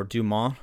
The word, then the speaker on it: Dumont: Santos Dumont, or Dumont, if you I guess want to do the French pronunciation.